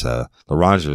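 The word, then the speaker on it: Loranger: Y'all don't know about LaRanger, how it's spelled. It's LORRANGER. And it's LaRanger is a very rural place in Tangipahoa Parish.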